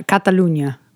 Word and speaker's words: Catalunya: Well, it says Catalonia. – Yeah, Catalunya. – Well, if you're in Spain, that's how you say it, right? – Yeah.